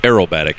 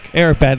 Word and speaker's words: aerobatic: They got all kinds of crazy acrobatic flyers. Is that how you say it? – Aerobatic. – Aerobatic.